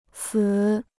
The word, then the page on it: 词: cí